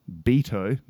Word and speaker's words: Beato: it's spelt rick beato b-e-a-t-o but it's rick beato